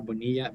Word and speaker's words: Bonilla: So Dr. Bonilla, B-O-N-I. LLA Onc as oncology.